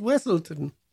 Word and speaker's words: Weaselton: Weaseltown. – Weaseltown. – Yeah. It's pronounced Weaselton.